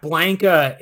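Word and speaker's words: Blanka: Blanca is how you're supposed to pronounce it.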